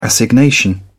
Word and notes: assignation: British English

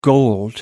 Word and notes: gold: American English

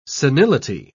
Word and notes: senility: British English

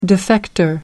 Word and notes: defector: British English

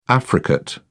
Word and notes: affricate: English; British English

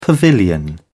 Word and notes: pavilion: British English

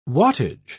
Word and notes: wattage: British English

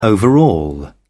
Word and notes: overall: British English

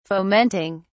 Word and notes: fomenting: British English

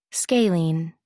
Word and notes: scalene: American English

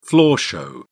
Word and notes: floor show: British English

